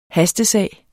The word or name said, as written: hastesag